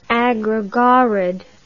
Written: agrogorod

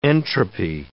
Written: entropy

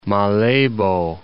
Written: Malebo Pool

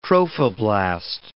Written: trophoblast

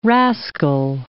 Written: rascal

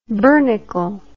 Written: bernicle

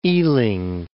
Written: Ealing

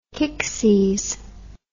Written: kicksies

